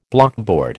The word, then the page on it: blockboard: American English